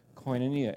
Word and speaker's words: koinonia: And we saw that there's a priority that we need to make for koinonia. And our friend Dustin told me, I think the correct pronunciation is gonna need you.